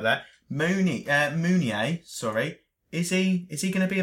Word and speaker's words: Meunier: How do you pronounce his name? – I think it's Meunier. – Meunier. Let's go for Meunier. I was going to go Mooney. Doesn't sound as good, does it?